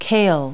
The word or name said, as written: kale